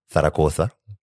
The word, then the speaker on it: Zaragoza: And so I have a packed schedule that includes evening tours of the museums and tourist sites in Zaragoza. You'll notice how I'm pronouncing it the correct way.